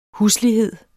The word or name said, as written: huslighed